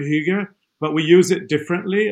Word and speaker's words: hygge: I love this concept of hygge. Did I say that right? – Hygge, yes.